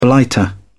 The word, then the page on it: blighter: British English